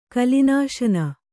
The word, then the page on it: ಕಲಿನಾಶನ: Kannada